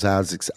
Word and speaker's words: Isaacs: My name is Isaac, I-S-A-C-S.